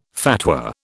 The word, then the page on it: fatwa: British English